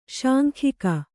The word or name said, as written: ಶಾಂಖಿಕ